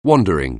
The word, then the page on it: wandering: anglais